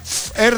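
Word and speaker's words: Fher: Fer con H, ¿no? – Fer. Así se pronuncia, Fer.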